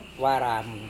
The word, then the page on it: waramu: Serewen